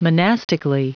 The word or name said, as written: monastically